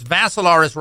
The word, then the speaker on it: Vassilaris: So Facebook live stream on the my profile page, which is Dimitri with three I's, D-I-M-I-T-R-I. The last name is Vassilaris, rhymes with Assilaris, but you add a V.